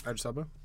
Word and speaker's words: Idris Elba: Oh yeah, Idris Elba.